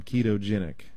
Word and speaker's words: ketogenic: You may not have heard the term ketogenic. That's spelled K-E-T-O and then G-E-N-I-C. Ketogenic.